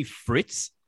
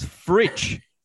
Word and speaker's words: Fritsch: The other thing is, can we tell Brad Johnson to stop calling him Bailey Fritz? It's Fritsch. Pronounce these names right.